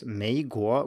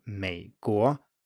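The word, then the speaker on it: Meiguo (美国): So for example, if you're an American and you pronounce your country as Meiguo with a rising tone on the first syllable, it's wrong. It should be a low tone, Meiguo.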